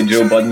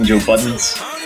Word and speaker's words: Joe Budden: It's almost like how a lot of people say Joe Budden, Joe Buttons.